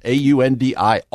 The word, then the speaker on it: Aundi: Aundi Kolber, A U N D I.